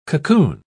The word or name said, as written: cocoon